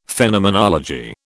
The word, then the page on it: Phenomenology: British English